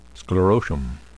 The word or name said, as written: sclerotium